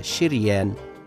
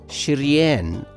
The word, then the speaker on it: شريان: أن كلمة شريان تنطق بكسر الشين وبفتحها فنقول شريان الأورطة وشريان الأورطة